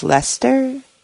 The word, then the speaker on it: Leicester: I run a group called Skeptics in the Pub in, I think it's pronounced Leicester, England, and we've had at least 3 or 4 people who have attended after listening to your show.